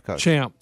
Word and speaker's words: Champ: I know, was it Chump and then Morikawa? – No. Champ. – Sorry, I pronounced it wrong.